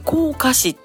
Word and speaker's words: 甲賀市: えー、そして滋賀県甲賀市甲賀市って書いて甲賀市